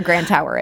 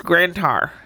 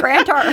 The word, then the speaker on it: Grand Tower: I bet even people who live in Illinois don't know where Grand Tower is. – They don't. And you know, you said it wrong. It's Grand Tower. – Grand Tower. I got to work on that. – Sorry. – Grand Tower.